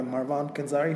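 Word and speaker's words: Marwan Kenzari: So for me, one of the big disappointments of the 19 Aladdin is Jafar, played by Marwan Kanzari, if I'm pronouncing his name right? – Yes.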